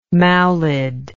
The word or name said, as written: maulid